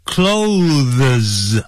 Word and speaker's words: clothes: 这个不要念成 clothes 啊，那是 C L O T H E S 在一起的时候呢，那要念 clothes，就好像 close the door 一样子，把门关起来，那个念法一样，C L O S E clothes。